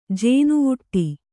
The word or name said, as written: ಜೇನುವುಟ್ಟಿ